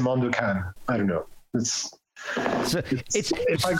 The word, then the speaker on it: Mondo Cane: Okay, and I've seen it, but been pronouncing it incorrectly the entire time. – it's Mondo Cane, but okay. For example, in French, when somebody asks me what's the name of my project, I say Mondo Cane, basically.